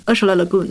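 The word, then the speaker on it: Ursula Le Guin: 有时候我就在想说，咱们回到这个科幻的话题，你说很多科幻作家，比如说呃，他的他他的发音应该怎么 lagoon 对吗？ – Lagoon，厄什拉 Lagoon，嗯呃呃，Lagoon 就是这个科幻作家，我我就看他的一些书的时候，我就想说，他眼里的世界是什么样的？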